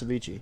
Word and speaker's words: Avicii: The one that's spelled A-V-I-C-C-E-I. Oh, well, that's Avicii.